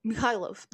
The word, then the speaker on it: Mikhailov: And he's like, Mikhailov. That's how you say it, Mikhailov.